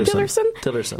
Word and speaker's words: Tillerson: Et oui, eh bien, Christian Freeland euh, jasait avec Rex Tillerson, je suis pas certaine de la prononciation. Tillerson.